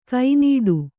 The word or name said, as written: ಗಿಣ್ಣಿಡು